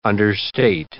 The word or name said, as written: understate